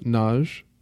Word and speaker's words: Nozh: These two are by Men Seni Suyamin, and this first one is titled Nozh, I believe is the pronunciation. It's N-O-Z-H, if you want to look that up.